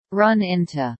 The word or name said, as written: run into